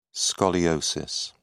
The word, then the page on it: scoliosis: British English